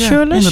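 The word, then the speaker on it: Shirelles: Ja, we luisteren naar Mama Set van de Shirless. Zeg ik dat goed? Shirless? – De Shirless, denk je. Shirless, inderdaad.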